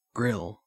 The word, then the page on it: grill: Australian English